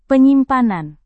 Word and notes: penyimpanan: indonesian

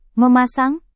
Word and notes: memasang: Indonesian

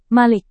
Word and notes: malik: Indonesian

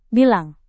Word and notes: bilang: indonesian

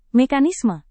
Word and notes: mekanisme: Indonesian

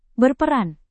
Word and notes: berperan: indonesian

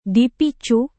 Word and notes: dipicu: Indonesian

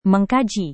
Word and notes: mengkaji: Indonesian

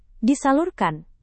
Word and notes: disalurkan: Indonesian

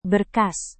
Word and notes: berkas: Indonesian

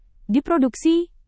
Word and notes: diproduksi: indonesian

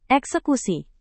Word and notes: eksekusi: indonesian

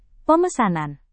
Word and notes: pemesanan: Indonesian

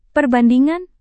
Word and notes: perbandingan: Indonesian

